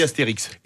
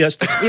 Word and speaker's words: Astérix: Et il faut dire aussi Chamonix. – Oui. – On ne prononce pas le Par X. Contre, on dit Astérix. – Et on dit Astérix.